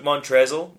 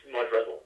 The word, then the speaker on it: Montrezl: I refuse to pronounce his name Montrez. It's Montrezl. – It's Montrezl. It's spelled that way.